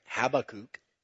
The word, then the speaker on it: Habakkuk: Once again, yep, I said it right, Habakkuk.